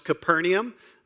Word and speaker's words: Capernaum: At least we believe that's how it's pronounced, Capernaum. The New Testament language is now a dead language, meaning people do not still speak it. But we believe it was pronounced Capernaum.